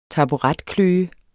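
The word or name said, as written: taburetkløe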